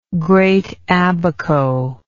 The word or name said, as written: Great Abaco